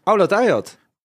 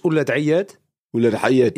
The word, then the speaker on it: Aoulad Ayad: En, maar je achternaam die spreek je uit als? Oulat Ayat. Oulat Ayat. Ja, en op zijn Arabisch Oulat Ayat.